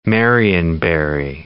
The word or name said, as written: marionberry